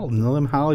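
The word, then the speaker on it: Limahl: With lyrics by Keith Forsey and performed by Lamile? We can never pronounce it. Lim Howell.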